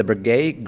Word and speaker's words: Breguet: Number three on the list is the Breguet Grand Complication Marie Antoinette, priced at $30 million.